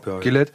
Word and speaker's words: Gilead: Es gibt, also da hat sich eine neue Gesellschaftsform gegründet in einer Stadt namens Gillette glaube ich, so wird es ausgesprochen, ne? G-I-L-E-A-D, ja, Gillette.